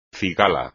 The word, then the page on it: cigala: espagnol